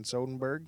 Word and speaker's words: Soderbergh: Steven Soderbergh. – Soderbergh. Yeah. – Soderbergh. – There's no N in there. It's not Soden.